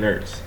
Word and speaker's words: Ertz: I don't know, I keep on saying his last name wrong. I heard it last night, and I was just like, that's how you say it? I've been saying it wrong the whole time. Like, oh, yeah, and Ertz.